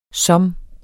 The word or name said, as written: som